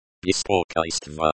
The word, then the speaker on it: Беспокойство: The stress is on the second syllable. Беспокойство.